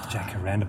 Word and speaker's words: jacaranda: Jacaranda, but you pronounced it quite well. – Was that right? – Yeah. – Chakaranda? – Jacaranda.